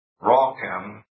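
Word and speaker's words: rochem (רקם): The word that they have used here, curiously, is a Hebrew word, number 7551, pronounced rochem.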